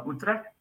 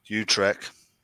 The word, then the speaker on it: Utrecht: Utrecht? Yeah. – Okay. – Utrecht.